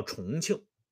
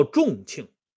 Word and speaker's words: 重庆: 这就叫重庆，啊，也叫重庆。而这个重庆呢，重量的重啊，这个重是多音字，这个重庆呢，跟中兴这两个字密切相关，因为重庆的谐音就是中兴，哎，所以在南宋啊，宋光宗被立为皇太子皇太子的仪式上。